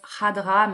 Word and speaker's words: Khadra: J'essaie de prononcer un peu à l'arabe, donc ça se prononce Hadra, mais ça s'écrit avec un K au début euh, et Yasmina Hadra, c'est le deux premiers prénoms de sa femme.